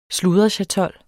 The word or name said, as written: sludrechatol